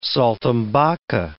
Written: saltimbocca

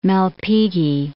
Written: Malpighi

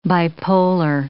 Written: bipolar

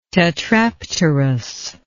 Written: tetrapterous

